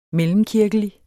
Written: mellemkirkelig

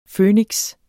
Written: Føniks

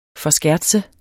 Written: forskertse

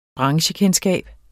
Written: branchekendskab

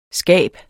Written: skab

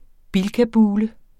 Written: bilkabule

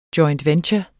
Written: joint

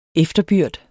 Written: efterbyrd

